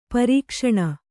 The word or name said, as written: ಪ್ರಕಾಶನ